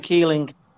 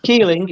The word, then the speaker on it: Keeling: Yeah, it's just my name, Gordon Keeling. AK Flash, okay. – Basically. – Well, you'll have to spell that for me. – Keeling, it's Gordon, Keeling, K-E-E-L-I-N-G, AKA Flash. – Okay, slow down. K-E-E. – Yeah, L-I-N-G.